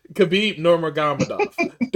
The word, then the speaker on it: Khabib Nurmagomedov: I'm going to get the name right. Khabib Nurmagomedov. There we go.